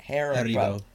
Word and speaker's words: Haribo: How do you say that? Haribo. Yeah, that one.